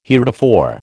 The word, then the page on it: heretofore: American English